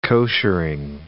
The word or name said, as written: koshering